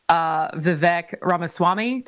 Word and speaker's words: Vivek Ramaswamy: And then obviously for Republicans, people who have announced so far are Nikki Haley, Vivek Ramaswamy.